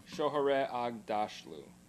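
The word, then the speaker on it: Shohreh Aghdashloo: Oh my gosh, there's a lot of Shohare Agdashlu.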